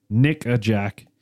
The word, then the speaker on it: Nickajack: All right, so we're going to move on to Nickajack, which it might sound like I'm saying something else because my southern accent, but it is spelled N-I-C-K-A-J-A-C-K. It's literally like the name Nickajack.